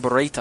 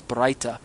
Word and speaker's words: Baraita: I was using the term Baraita. I apologize. It's Baraita. I was mispronouncing it.